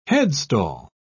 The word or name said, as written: headstall